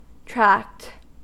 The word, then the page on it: tract: American English